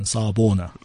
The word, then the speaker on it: Sawubona: Help me with my pronunciation. Sawubona. – Yes.